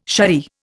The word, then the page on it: saree: Indian